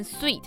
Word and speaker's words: suite: Sweet 套房的发音跟甜食 sweet 相同，而 suit 套装跟 sweet 只差一个 e，但发音很不一样。这边的 ui 发 u 的音，好特别哦。 S-U-I-T-E 竟然是念 sweet。